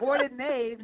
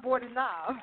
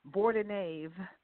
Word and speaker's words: Bordenave: I said it's Bordenave, not Bordenave.